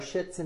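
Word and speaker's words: Schitz: It's spelled differently, folks. It's okay. – We have our Shits and Giggles. – Spelled C S-C-H-I-T-Z, so it's perfectly fine to say on air.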